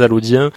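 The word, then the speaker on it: Allaudiens: Et c'est pour ça que ça se prononce non pas les Allochiens, bien heureusement, mais les Allodiens, parce qu'on considère qu'Allo, à l'origine, s'écrivait avec un D.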